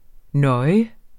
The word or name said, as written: nøje